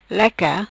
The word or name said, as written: Leca